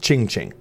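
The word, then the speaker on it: Qing Qing: Qing Ping is in Chinese. It's pronounced Ching Ching.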